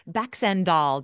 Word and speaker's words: Baxandall: Baxendall, did I say that right? – You got it.